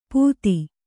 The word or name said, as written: ಪುದಿ